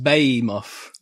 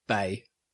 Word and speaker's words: Behemoth: Behemoth. – Behemoth. – Behemoth. – Behemoth. Behemoth. – Bay.